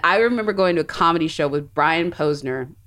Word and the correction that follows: Posehn: which you probably know. – Posehn. – I'm getting his name wrong.